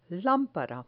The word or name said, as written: lampara